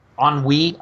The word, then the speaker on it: ennui: Ennui, I believe it's pronounced. E-N-N-U-I. – Yes, ennui.